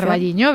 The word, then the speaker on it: O Carballiño: O Carballiño, no lo sé pronunciar. – O Carballiño, bien, o exacto.